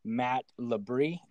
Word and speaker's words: Matt LeBris: I'm your host, as usual, and I have the wonderful Matt LeBris.